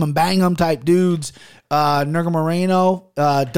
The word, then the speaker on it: Nurmagomedov: Nurmagomedov versus Kennedy and Zekchukwu.